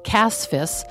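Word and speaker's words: CASFS: Systems, CASFIS, as I finally remembered to pronounce correctly.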